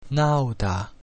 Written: nauta

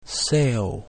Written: seu

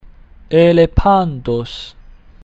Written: elephantus

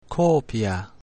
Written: copia

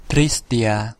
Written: tristia

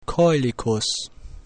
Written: coelicus

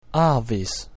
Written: avis

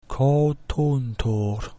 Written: coutuntur

